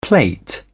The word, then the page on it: plate: British English